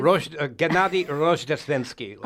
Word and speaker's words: Gennady Rozhdestvensky: Gennady Rozhdestvensky, ladies and gentlemen.